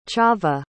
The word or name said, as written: chava